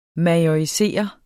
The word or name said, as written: majorisere